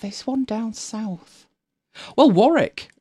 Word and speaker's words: Warwick: There is a W in the middle of it, but it's not. It's Warwick.